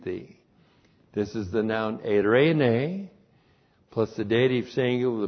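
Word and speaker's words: eirene (εἰρήνη): Eirene. Great, great word in the Greek, and it has an equivalent in the Hebrew, shalom. E-I-R, long E, N, long E, eirene.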